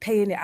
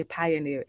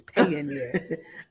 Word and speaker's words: Payoneer: Payoneer, I would say Pioneer. It's Payoneer. – Brenda Lee, I know Brenda Lee, and Brenda Lee is from Jamaica. She uses something too. I don't know if it's Payoneer, but I've heard her use one because she's in the Caribbean.